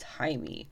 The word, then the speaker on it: Jaime: And yes, it is spelled with a J, but it is pronounced Jaime.